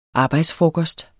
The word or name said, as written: arbejdsfrokost